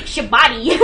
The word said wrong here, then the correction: Shabti: Well, I can't remember if wax figures are different to Shabti. – Shabti! I knew I said it wrong, and I said it wrong both times, but you know, that's fine.